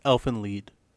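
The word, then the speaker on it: Elfen Lied: It's pronounced lead. – Just is it lead? – It's Elfenlied. – I really appreciate that. I've never known that. – It's German, yeah. I used to pronounce it lied too, and someone told me.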